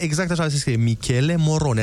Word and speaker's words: Michele Morone: Exact cum se citește. Michele Moro... Morone. Michele Morone, nu e nimic complicat.